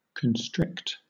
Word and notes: constrict: British English

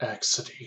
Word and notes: accidie: American English

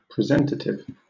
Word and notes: presentative: British English

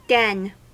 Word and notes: den: American English

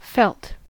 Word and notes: felt: American English

